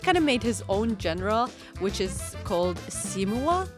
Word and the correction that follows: Simigwa: That would be Simigwa. Yeah, Simigwa. – It's very phonetic with African languages. It's like A, E, O, U with the vowels and pronunciation and stuff like that. – I was confused on like Simigwa, whether the G is like pronounced or not.